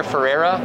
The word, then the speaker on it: Ferreira: Hi, my name is Junior Ferreira, and I'm the esports manager for Arc System Works America. And my name is spelled out J-U-N as in Nancy, I-O-R. Last name is F as in Frank, E-R-R-E-I-R-A.